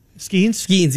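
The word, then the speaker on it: Skenes: We had, you can't say his name, Paul Skews? – Skeens, yeah.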